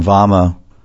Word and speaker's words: Vama: Vyogini is also called Vama, V-A-M-A, long A, Vama.